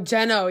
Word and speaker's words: Jeno: he has such a very similar face to Gino from NCT Dream. And, like... – Oh, Geno, yeah. – Oh, Geno, sorry. I always just want to Geno. It's spelled like that.